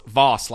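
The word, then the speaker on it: Vos: They're like, no, it's Voss, like sauce.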